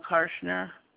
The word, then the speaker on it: Karshner: Paula Karshner. – You'll spell your last name for me? – K-A-R-S-H-N-E-R.